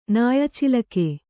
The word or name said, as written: ನಾಯಚಿಲಕೆ